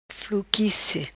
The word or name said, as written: Fluquice